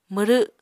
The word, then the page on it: mwrw: Apatani